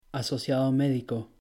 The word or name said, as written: asociado médico